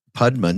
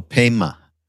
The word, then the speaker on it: Pema: And is it spelled like Padma, but with a Y instead of a D? – Padma, no, Pema. – But it's spelled like Padma, P-A-Y-M-A. – No, P-E-M-A, like it sounds. – Oh. – Chodron, C-H-O-D-R-O-N.